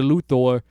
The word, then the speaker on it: Luthor: You know, there's going to be a scene in there, I bet, where Lex Luthor punches... Oh, why did I say it like the old movies? Mr. Luthor. Lex Luthor punches Superman in the chest.